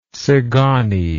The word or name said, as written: tzigany